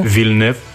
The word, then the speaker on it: Villeneuve: Villeneuve, more in a French way.